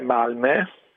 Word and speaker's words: Malmö: La pronuncia è Malmö, con quella la O con i due punti sopra, che a differenza che in tedesco non è da considerare come una dieresi, ma è proprio una vocale a sé stante a questa pronuncia, che è una E.